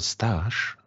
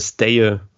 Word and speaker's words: Stage: Ja, ich glaube, in Dänischen wird dieses G ähm, wie ein J ausgesprochen. Also ich habe überall gehört, dass der Staye ausgesprochen hm.